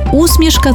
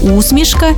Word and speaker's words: усмішка: Виявляється, що усмішка – це про невербальне транслювання чогось доброго, смішного. До речі, тут подвійний наголос. Усмішка і усмішка, як у Василя Симоненка.